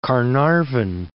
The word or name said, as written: Caernarfon